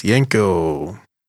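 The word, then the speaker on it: Yenko: Yinko. – Yinko? – Mm-hmm. Is that how you say it? Yinko? – Yes, Yinko.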